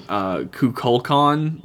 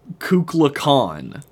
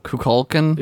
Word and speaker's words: Kukulkan: I did want to point out that besides Kirk mispronouncing Kukulkan the whole fucking episode... – How does he say it? – He says... Kukla Khan, like Kubla Khan, but with a K.